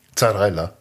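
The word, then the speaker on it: Zarrella: Zarella. Das ist Zarella mit Z. Okay. Z, wie wir Deutschen das aussprechen, so heißt der.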